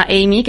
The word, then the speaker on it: AMIE: De hecho, ahora mismo, pues mira, hay una inteligencia artificial que se llama Amy, que es de Google.